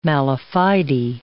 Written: mala fide

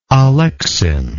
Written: alexin

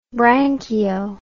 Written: branchio

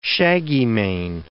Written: shaggymane

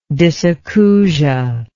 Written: dysacousia

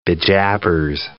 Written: bejabbers

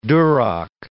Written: duroc

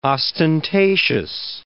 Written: ostentatious

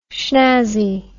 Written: shnazzy

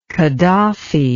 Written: Khadafy

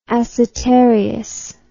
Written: acetarious

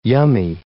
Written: yummy

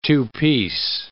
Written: two-piece

